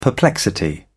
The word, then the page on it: perplexity: British English